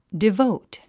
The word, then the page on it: devote: divóut; デヴォウト